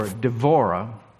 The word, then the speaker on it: Devorah: And in this story, Deborah, or Devorah, all the B's sound like V's in Hebrew.